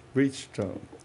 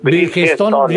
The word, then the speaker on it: Bridgestone: A ver, que ¿cómo se dice Bridgestone o Bridgestone? – Bridgestone. – Bueno, depende de quién lo diga. – Vamos, aquí lo dice que dice que es Bridgestone. Bridgestone.